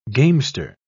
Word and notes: gamester: British English

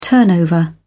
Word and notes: turnover: British English

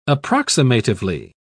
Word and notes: approximatively: British English

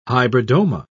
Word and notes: hybridoma: British English

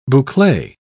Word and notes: boucle: British English

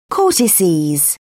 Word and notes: adrenal cortex: American English

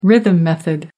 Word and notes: rhythm method: American English

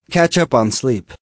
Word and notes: catch up on sleep: American English

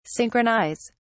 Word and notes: synchronise: British English

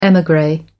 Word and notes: emigre: American English

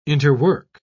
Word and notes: interwork: British English

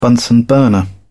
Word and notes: bunsen burner: British English

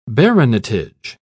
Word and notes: baronetage: British English

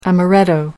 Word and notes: amaretto: American English

